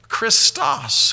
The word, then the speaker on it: Christos: And that verb is obviously the action part of a noun, which is pronounced this way, Christos.